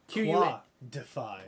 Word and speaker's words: Quadefy: Well, while we were in Mackinac, we picked up a game at a toy store there called Quad-Defy. I don't know how to pronounce it. – Quad-Defy. – Quad-Defy. Q-U-A-D-E-F-Y.